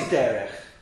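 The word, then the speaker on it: derech (דרך): The word is always derech.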